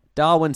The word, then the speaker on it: Darwin: Darlin? – Darlin? Learn to pronounce your words better. – Fuck. Darwin seems fine.